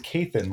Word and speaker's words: Kathan: Actually, my name is pronounced Kathan, like Nathan with a K.